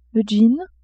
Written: jean

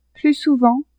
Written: plus souvent

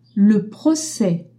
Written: proces

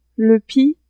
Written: le pis

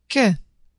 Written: que

